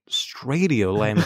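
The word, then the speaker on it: Straightio: And I mean, Sam said, he said Radiolab, and I thought, and we thought, well, Stradio rhymes with radio.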